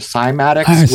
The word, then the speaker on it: cymatics: You are really into a term or a thing called semantics, right? – I think maybe you're referring to cymatics. – Cymatics. Yeah. I'm just pronouncing it incorrectly.